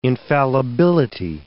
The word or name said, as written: infallibility